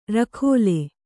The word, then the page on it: ರಖೋಲೆ: rakhōle; Kannada